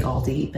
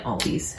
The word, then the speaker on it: Aldi: I think the only one that I do it accidentally is Aldi's. I know the name of that store is actually Aldi, but I cannot stop calling it Aldi's.